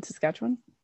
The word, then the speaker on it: Saskatchewan: Oh, um, Saskatchewan.